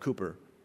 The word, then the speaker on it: Cowper: William Cowper, it's spelled C-O-W-P-E-R. It's actually technically pronounced Cooper.